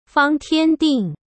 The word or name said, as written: Fang Tianding